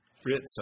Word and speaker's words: Fritz: And the police came into the store, and he said, you're not, and John, you can maybe help me with his name, Fred Chrysler. Is that how you pronounce it? Fritz, okay. Yeah, well, they said, you're not Fritz Chrysler.